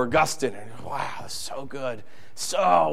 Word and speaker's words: Augustine: If you had Martin Luther or St. Augustine. By the way, it's pronounced Augustine, not Augustine, but, you know, it's one of my pet peeves. It's Augustine. Jesus still loves you if you say Augustine.